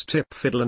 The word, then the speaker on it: TPFDL: Back then this complaint about messing with the painstakingly developed TPFDL, an awkward acronym that military types pronounce TIPFIDL